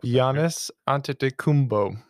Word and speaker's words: Giannis Antetokounmpo: Help Giannis Antetokounmpo.